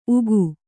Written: ಊಗು

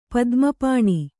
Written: ಪದ್ಮಪಾಣಿ

